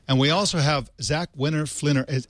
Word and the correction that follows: Fligner: How do you say your name, Zach? I'm so sorry. – It's Winner Fligner. – Fligner.